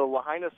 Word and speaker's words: Lahaina: I would try to pronounce the name Lahaina Civic Center. Is that what it is? – You got it. The Lahaina Civic Center. – The Lahaina Civic Center. Wanted to make sure of that.